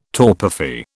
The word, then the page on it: torpefy: British English